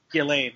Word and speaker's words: Ghislaine: Ghislaine, which I didn't know how to pronounce it until I saw this.